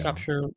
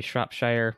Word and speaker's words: Shropshire: Shropshire, Louisiana. Yeah, and it's... I looked it up, and I was like, oh, this looks like it should be Shropshire, which is the American pronunciation, but Shropshire.